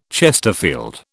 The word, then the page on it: chesterfield: British English